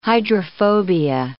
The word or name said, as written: hydrophobia